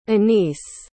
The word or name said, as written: Anis